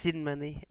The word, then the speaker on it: Sinmany: Sinmany, S-I-N-M-A-N-Y.